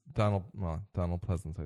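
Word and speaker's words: Donald Pleasence: And this is Donald Pleasance, I said it right.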